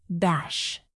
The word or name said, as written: bash